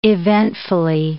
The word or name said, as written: eventfully